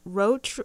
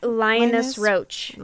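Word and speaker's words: Linus Roache: How do you say his name? Roach? Roche? – Oh, Linus Roach.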